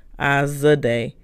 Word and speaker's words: Azadeh: It's spelled A-Z-A-D-E-H. It's actually a Persian name, and it's actually pronounced Azadeh, but my family pronounces it Azadeh with the J, but there is not a J nowhere in my name.